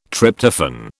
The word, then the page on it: Tryptophan: British English